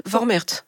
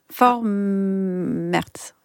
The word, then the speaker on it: Vormärz: Vormärz. – Vormärz.